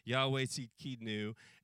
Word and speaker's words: Yahweh Tzidkenu: It's kind of an interesting pronunciation of an Old Testament Hebrew word. Yahweh Tzidkenu, and that would be the Lord our righteousness.